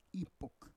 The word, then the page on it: ipok: Roon